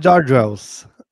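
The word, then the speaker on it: George L's: Eu estou usando, vamos lá com a minha pronúncia maravilhosa aí, o George LS, como é que é? – O, é, pessoal chama de George Wells. – É isso, exatamente.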